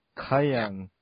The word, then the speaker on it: Cayenne: No, it's Cayenne, but you have to really swing the Y to pronounce it properly. So it's Cayenne, like that. – Ah, Cayenne.